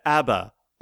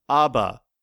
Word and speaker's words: ABBA: However, it is universally recognized that ABBA, ABBA, ABBA, ABBA, pretty much anything that vaguely recognizes an A sound in some language with two B's in the middle is a relevant pronunciation.